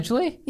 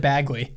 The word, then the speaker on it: Badgley: It Badgley? – Yeah. – I always in my head I pronounce it Bagley.